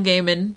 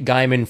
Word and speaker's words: Gaiman: And I just remember the host kept pronouncing it Gaiman, and I kept screaming at the TV. – I pronounced it Gaiman for years until you yelled at me. It's Gaiman.